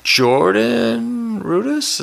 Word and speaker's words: Jordan Rudess: Jordan Rudess, is that how you say it? – Yeah.